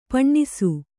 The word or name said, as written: ಪಣ್ಣಿಸು